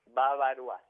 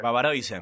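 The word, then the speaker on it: bavarois: Babaroa se pronuncia. Pero Babaroise.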